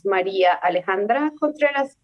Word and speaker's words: Maria Alejandra Contreras: Well, my full name is Maria Alejandra Contreras.